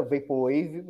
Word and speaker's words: Vaporwave: Primeiro, é uma coisa certa, a sua pronúncia está completamente correta, Vaporwave, mas por hábito, ou por vício de linguagem, eu provavelmente vou acabar chamando de Vaporwave uma hora.